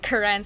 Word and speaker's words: Karen: um, but it's actually pronounced like Karen, so there's like a s- emphasis on the second syllable, and that's like um, so they're an eth- uh, they're an umbrella group in Myanmar.